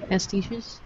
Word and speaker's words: pastiches: Um, one of the things that I don't like about a lot of Lovecraft um, pastaches. Am I saying that right? – Pastiches.